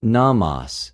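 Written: νομος

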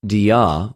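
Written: δια

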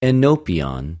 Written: ἐνωπιον

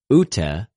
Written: οὐτε

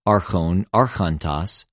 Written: ἀρχων, ἀρχωντος

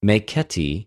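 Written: μηκέτι

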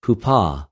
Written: ὑπο

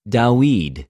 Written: Δαυιδ

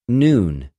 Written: νυν

